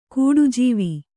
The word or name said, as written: ಘಟಜೀವಿ